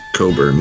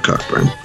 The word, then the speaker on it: Cockburn: And then at the end of the conversation, the guy's like, "By the way, his name is Bruce Coburn, not Cockburn."